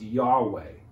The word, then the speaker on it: Yahweh: In the Old Testament, the Hebrew word for the divine name of God was probably pronounced Yahweh, though when it was written down, they did not include any of the vowels as a sign of reverence.